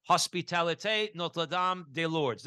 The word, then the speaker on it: Hospitalité Notre-Dame des Lourdes: Now, Marlene is a wife, mother, grandmother, secular Franciscan, and member, I'm going to butcher this because it's in French, member of the Hospitalité Notre-Dame des Lourdes. Did I say that right, Marlene? – Fabulous.